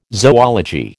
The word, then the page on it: zoology: American English